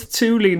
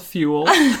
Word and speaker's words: Thule: You remember when we had Thule bags and we were like, thanks, Thule. Literally pronouncing it wrong. Oh my God. Terrible children. – Someone commented on YouTube and was like, ha ha ha ha ha, it's Thule, not Thule.